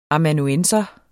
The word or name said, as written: amanuensis